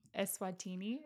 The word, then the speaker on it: Eswatini: You in high school being in this little small place called Eswatini.